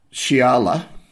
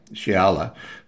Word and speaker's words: Xiala: Shiala, always pick on names that are difficult to pronounce. X-I-A-L-A, Shiala, who's a woman of the mysterious Teak people, and who's also an exiled sea captain.